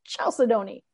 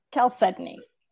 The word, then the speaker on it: chalcedony: And then, so you might have, one of the words that I have to work really hard at pronouncing correctly is chalcedony, because when you look at it, it looks like chalcedony. – Yeah. Yeah. – Every time I want to go chalcedony and it's not, it's chalcedony. And I'm like, why would you make such an awkward pronunciation of that word? – Yeah.